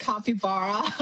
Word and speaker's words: capybara: How do I say it, Iris? – I call it capybara.